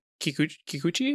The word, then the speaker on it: Kikuchi: And before we get into happy stuff, so in the past week, the composer for a lot of Dragon Ball music, Shunsuke Kikuchi, I believe is how you say it. – Yes.